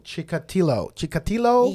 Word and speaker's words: Chikatilo: So with that being said, we're going to talk about Andre Chikatilo. – Ugh, I think that's gross. How you say his name. Chikatilo.